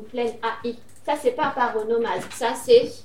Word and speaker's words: paronomase: C'est pas paranomase. – Presque ça, tu l'as. Paronomase. – Paronomase.